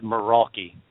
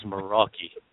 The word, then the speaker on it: Meraki: But you guys will have to correct me, Maruki? How do you say that? – Maruki, until Daniel Maddish was on the show. And he said Meraki. He corrected me. It was Meraki.